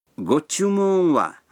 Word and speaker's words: ご注文は: Го-чу-мун-ва означает «что бы вы хотели».